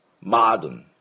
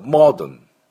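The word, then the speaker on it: modern: マードン、マードンね、というふうに発音します。で、アメリカ人の音でした今のは。ではイギリスになるとモードン、モードンみたいな、最初の音がアメリカ人はちょっと喉をめちゃくちゃ開けるんで、あのちょっとアに近くなってマードンの音になりますが、イギリス人はマードン、奥でオッというのを出します。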